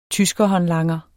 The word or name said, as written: tyskerhåndlanger